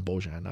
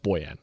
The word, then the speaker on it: Bojan: He calls him Bojan. I like that. I'm pretty sure Boyan has its pronounced Boyan. Like, that's what we were told when he first got here.